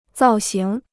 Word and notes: 造型: zào xíng

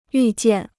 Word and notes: 预见: yù jiàn

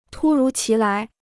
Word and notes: 突如其来: tū rú qí lái

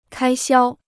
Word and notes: 开销: kāi xiāo

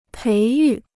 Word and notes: 培育: péi yù

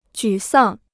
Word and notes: 沮丧: jǔ sàng

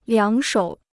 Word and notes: 两手: liǎng shǒu

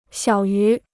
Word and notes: 小于: xiǎo yú

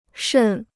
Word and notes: 渗: shèn

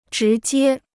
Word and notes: 直接: zhí jiē

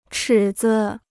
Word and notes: 尺子: chǐ zi

